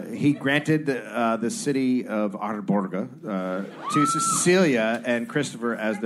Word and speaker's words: Arboga: Then in late 1571, John granted the small city of Arboga to Cecilia. Did I butcher? Arboga? Arboga. Yeah, you gotta roll the R's. It's like a small Arboga.